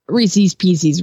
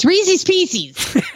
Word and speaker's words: Reese's Pieces: I don't know or care about any of those things except for the fact that you're pronouncing Reese's Pieces wrong. It's Reese's Pieces.